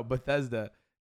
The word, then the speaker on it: Bethesda: I think I was saying it wrong. Bethesda.